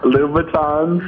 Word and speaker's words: Louboutins: Louboutins.